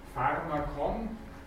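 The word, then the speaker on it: φάρμακον: Beginnen mit einem Vieh Alpha Rho Mu Alpha Kappa Omikron μ. Betonung über dem ersten Alpha. Pharmakon, das heißt ganz einfach das Heilmittel.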